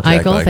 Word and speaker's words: Eichel: Eichel. – Last name. Eichel, thank you.